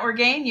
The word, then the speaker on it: Orgain: I'm Diana Orgain, USA Today bestselling author of the Maternal Instincts Mystery Series.